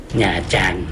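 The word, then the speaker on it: Nha Trang: หญ้าจันนะฮะถ้าเขียนถ้าเห็นเป็นภาษาอังกฤษจะอ่านว่านาตรังนะฮะแต่จริงๆอ่านว่าหญ้าจัน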